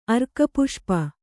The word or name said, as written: ಅರ್ಕಪುಷ್ಪ